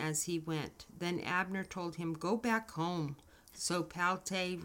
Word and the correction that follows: Palti: returned. – Now, I want to stop right there. I feel so bad for Palti. – It's Palti, yeah.